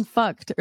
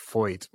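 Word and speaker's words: Feucht: Supposedly it's pronounced Foyt, which I don't know how you get to that from... the way his name is spelled, but that's me really.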